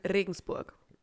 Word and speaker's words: Regensburg: Regensburg.